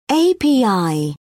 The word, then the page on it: api: British English